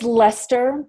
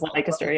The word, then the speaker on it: Leicester: It's Leicester, but a lot of Americans mispronounce it as Leicester, yeah.